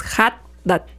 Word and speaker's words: Haddad: Y yo dije, Haddad, o sea, la H suena como J. Uh-huh. Me dice, no, pues lo haces más, es Haddad.